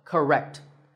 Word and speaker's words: correct: There are again two syllables, ka and rect, but in this word, we stress the second syllable, rect, so correct. Notice how that stressed syllable is said with a slightly louder volume, and it also sounds longer, correct.